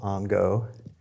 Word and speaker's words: Ango: Ongo is spelled A-N-G-O, Ongo.